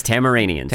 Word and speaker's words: Tamaranians: You both pronounced it wrong. – Yeah, sorry. – It's Tamaranians.